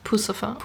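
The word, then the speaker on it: Puscifer: Genau, zum Beispiel Maynard James Keenan, der auch bei Perfect Circle oder Pussifer, spricht man das aus? – Also ich sag Pussifer. – Pussifer, genau.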